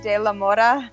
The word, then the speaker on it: De La Mora: I know, like, with the Spanish accent, it's like De La Mora.